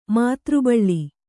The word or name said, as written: ಮಾತೃಬಳ್ಳಿ